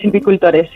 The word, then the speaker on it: silvicultores: Es ganadera y miembro de la Junta Directiva de UGAMA, la Unión de Agricultores, Ganaderos y Silvicultores. Torres, creo que lo he dicho bien, de la Comunidad de Madrid. Buenas tardes. – Hola, buenas tardes. Oye, sí, lo has dicho bien, es silvicultores.